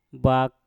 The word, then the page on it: bak: mpur